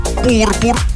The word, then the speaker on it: пурпур: Как нужно произносить слова. Итак, просека, пурпур, бутики, пуловер и манты, когда будете заказывать, именно так произносите.